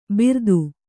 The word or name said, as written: ಬಿರ್ದು